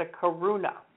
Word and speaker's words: karuna: Okay, the second one is Karuna, K-A-R-U-N as in Nicholas, A, Karuna. So you're sitting under your tree. Somebody hands you a beer. It's not a Corona. It's a Karuna.